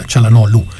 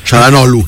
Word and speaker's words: Çalhanoğlu: La G è muta, Çalhanoğlu. – Çalhanoğlu. – In turco la G con il taglietto non si pronuncia.